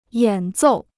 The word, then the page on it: 演奏: yǎn zòu